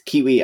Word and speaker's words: Keywe: It's Kiwi as in the bird, but it's spelt key as in keyboard key, K-E-Y-W-E, we as in us.